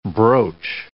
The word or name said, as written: broach